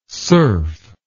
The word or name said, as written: surf